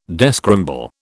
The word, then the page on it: descramble: British English